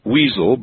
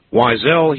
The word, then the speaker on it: Weisel: Now, he spells his last name W-E-I-S-E-L. And there would be a lot of people out there, cruel people, I'm sure, who would pronounce his last name Weasel, but Weasel he is not. Wiesel he is. Is it Wiesel? – Yep, that's the name. You got it right the first time.